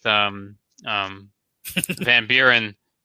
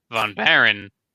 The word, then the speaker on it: von Baron: I almost said it wrong, like Brick Revolution. Brick Revolution is too ingrained with Van Buren, the president. he's losing sight of the fact that it's von Baron from the Adventurers.